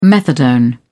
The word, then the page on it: methadone: British English